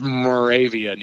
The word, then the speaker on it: Moravia: Yeah, it's actually pronounced Moravia, New York. – Moravia. Thank you. Yeah. I think I said Monrovia at one time. – Oh yeah, well, people pronounce it wrong all the time.